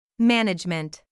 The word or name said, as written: management